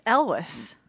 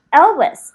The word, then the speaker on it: Elwes: Okay, well, like, literally, as you called, I finished As You Wish by Carrie, how do you say his last name? Always? Always? The guy that was in Princess Bride. – I think it's Elwes. – Elwes.